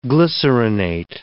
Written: glycerinate

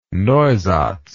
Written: neusatz

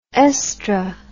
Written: oestr

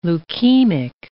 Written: leukemic

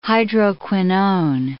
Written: hydroquinone

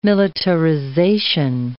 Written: militarization

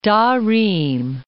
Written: Tarim

